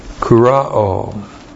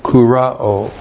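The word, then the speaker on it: Kurao (κυρόω): Kurao. K-U-R-O, long O. Kurao.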